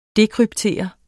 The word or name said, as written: dekryptere